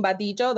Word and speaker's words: Badillo: I think it's because I'm Latina, so I'm saying Badijo. That's how you say it in Spanish. But you said it right in English.